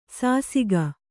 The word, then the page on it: ಶಶಕ: śaśaka